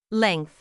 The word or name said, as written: length